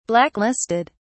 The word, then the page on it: blacklisted: British English